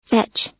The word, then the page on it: fetch: American English